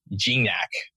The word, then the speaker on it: Gignac: So with all of that, I'm super excited to introduce you guys to Justin Gignac.